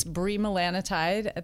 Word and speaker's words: bremelanotide: Bremelanotide, is that how you pronounce it? And it's the brand name will be Vilesi. – Yes, and it's bremelanotide. That's correct. It's basically one of those brain neurochemicals that affects desire in women and in men.